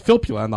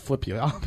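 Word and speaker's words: Filppula: Of course, I meant Filippula, not Filippula, but whatever.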